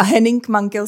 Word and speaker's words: Henning Mankell: A Henning Mankell se vyslovuje tak, jak si to přečetl krásně. Tam není složitost žádná.